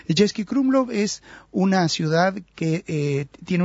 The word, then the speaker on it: Český Krumlov: Český se escribe C-E-S-K-Y, y Krumlov se escribe como se pronuncia, solo que con K.